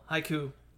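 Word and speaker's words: Haikyu: One, because the only reason I say that is because, like, don't get me wrong, I do like Haiku. I don't know why I always add the extra syllable. Haiku,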